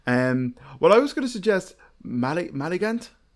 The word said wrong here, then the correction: Malignant: Malignant. Malignant. Oh my god, did I really just say that? I butchered that, mm.